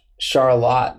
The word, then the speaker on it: Charlotte: Charlotte, that's how it's pronounced. So it's spelled the same as Charlotte, North Carolina. – It's a little town in Michigan, and it's called Charlotte. And I think it's pronounced that way because it was named after the wife of the founder, one of the founders of the city. And her name was Charlotte. It was pronounced the French way.